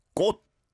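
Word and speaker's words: kot (꽃): The pronunciation sounds like "kot" in my ear anyway. So "kot" is flower. That's got the sangkyo, "kot." And "kot" is the single kiok and a digit bachim as well. And the way you really feel the difference is when there is an iung on the next syllable. I know in yolakake there is an iung, but it's not attached to kot. But if it is, you would have, for instance, with flower, gochi, right?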